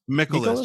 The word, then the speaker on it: Mikolas: Mikolas, just like Nicholas with an M, yeah.